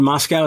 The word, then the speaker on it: Moscow: He must have been a little frustrated in this one because neither Christian Bale nor David Moscow, is that how you say it? – Yes.